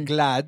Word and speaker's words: GLAAD: Sí, siempre se escucha sobre GLAD, que se escribe G-L-A-A-D.